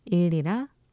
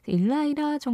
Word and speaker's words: Ilira: 일리라 Flowers 들으셨는데요. 스위스의 싱어송라이터예요. I-L-I-R-A 일라이라 정도로 발음해야 되나? 라고 생각을 했는데 그 발음 기호를 좀 찾아보니까 스위스식으로는 일리라가 좀 더 맞는 발음이라고 하네요.